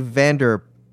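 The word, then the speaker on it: Van der Poel: In the poll, the OE is pronounced like the one in shoe. So it's Matthew Vanderpoel.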